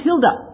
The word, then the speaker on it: Hilde: Her name's Hilde. H-I-L-D-E. Like the heta. The E on the end is pronounced ah. So she's Hilde.